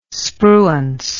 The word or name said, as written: spruance